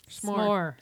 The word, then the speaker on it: s'more: S'more. – S'more. – S'more. – Or? S'more. S'more. But it's spelled S-M-O-R-E. – Mm-hmm. S'more. – S'more. – Apostrophe. – One syllable.